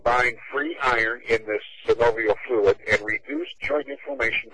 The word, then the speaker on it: synovial: It says LF, that's his terminology, which means lactoferrin in chemical terms, is also a major bioactive component of the synovial, is that how it's pronounced? Synovial fluid? – Synovial.